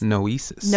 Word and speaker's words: Noesis: And your book is, how's it pronounced? Noesis? – Noesis. – Noesis. – Strong E.